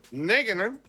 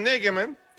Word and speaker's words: Nagelsmann: Habló también el director técnico de la selección nacional más joven de toda la justa deportiva, Julian Nagelsmann. Ojalá que no haya devastado. ¿Así se pronuncia, pueblo hermano? – Está perfecto, sí. Mi voz, está perfecto. – Julian Nagelsmann,